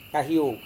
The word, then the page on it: kahio: Serewen